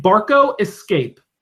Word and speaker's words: Barco Escape: So there's this new technology called the Barca. What? How do you say it? Let me say it so I could say it correctly. – Barca Lounger? – No, it's called the Barco Escape.